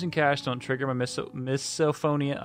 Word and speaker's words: misophonia: It's misophonia. – Misophonia, thank you.